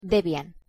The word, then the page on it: Debian: Spanish